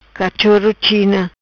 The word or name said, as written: China cachoro